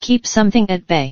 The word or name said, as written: keep something at bay